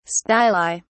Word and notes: styli: British English

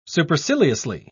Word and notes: superciliously: British English

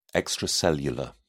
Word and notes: extracellular: British English

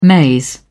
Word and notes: maize: British English

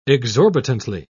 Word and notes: exorbitantly: British English

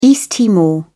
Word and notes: East Timor: British English

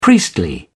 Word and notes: priestly: British English

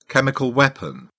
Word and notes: chemical weapon: British English

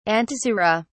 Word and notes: antisera: British English

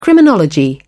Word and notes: criminology: British English

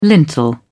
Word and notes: lintel: British English

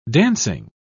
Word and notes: dancing: British English

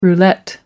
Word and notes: roulette: American English

